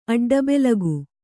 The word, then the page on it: ಅಡ್ಡಬೆಲಗು: aḍḍabelagu